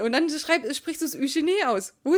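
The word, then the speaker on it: Eugénie: Wo ist der denn schrecklich? Eugenie. Das sind 3 Silben. Eugenie.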